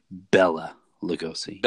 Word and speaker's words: Bela Lugosi: Now, just on a pronunciation note, do you say Bela Lugosi or Bela Lugosi? – I go with the Bela Lugosi. – Bela, okay. That's kind of what I would think, too.